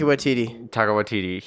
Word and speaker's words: Waititi: Taika Waititi.